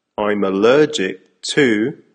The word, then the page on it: I'm allergic to: American English